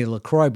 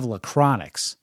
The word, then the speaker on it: LaCroix: So if you ask somebody to get you a LaCroix out of the refrigerator, Grace would say, we don't have any LaCroix, but we have Lachronix. And then we'd say, no, Grace, it's pronounced LaCroix. There's no N in the spelling.